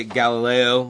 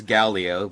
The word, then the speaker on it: Gallio: This guy's name, I keep wanting to pronounce it Galileo, but it's not. It's Gallio.